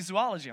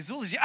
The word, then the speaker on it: zoology: I'm going to school to study zoology. I don't know why you would go to school to study that. Maybe. The way you say it is not zoology. It's zoology. I'm going to go study zoology.